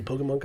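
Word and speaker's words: Pokémon: like a stake in Pokemon Company. – Oh, first off, you're not saying it right, by the way. It's Pokemon! You know, say it like that.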